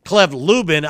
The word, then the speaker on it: Clev Lubin: Clev Lubin, I believe, is the pronunciation, was a beast for Coastal Carolina as an edge rusher this past year, had 9.5 sacks.